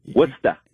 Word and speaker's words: Worcester: I always wanted to find out the reality of Assumption College in Worcester, Mass, because no one's ever pronounced that right that didn't live there, correct? – Yeah, Worcester. – You got to get the New England accent.